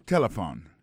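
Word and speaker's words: telephone: Premier bagage nous t'étendons, c'est téléphone. Téléphone, c'est comme ça, on dit téléphone en anglais. Nous devons répéter l'encore pour nous, téléphone. N'a pas remarqué que l'accent tonique l'a tombé sous premier syllabe là.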